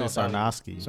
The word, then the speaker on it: Sarnoski: So Sarnoski? – Yeah, Sarnoski. Yeah, yeah, yeah. I, was, I, I thought I was going to say Sarnoski.